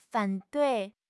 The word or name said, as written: fan3 dui4